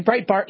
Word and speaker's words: Breitbart: Looks like we got news on the cause of death of Andrew Breitbart, if I'm pronouncing that name, Breitbart, yes.